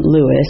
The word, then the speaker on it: Lewis: It's L-I-L-L-I Lewis, L-E-W-I-S, good old Welsh last name.